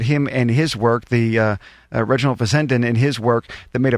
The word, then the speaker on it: Reginald Fessenden: Is that how you pronounce his name, Reginald Fessenden? – That's right, yes.